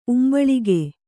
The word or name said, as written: ಉಂಬಳಿಗೆ